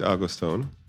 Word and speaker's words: Augustown: Is my pronunciation good? – Yeah, I mean, it's fine. Yeah, Augustown, yeah. – Okay, well, you've been posh. – No, man, in Jamaica, you call it Augustown.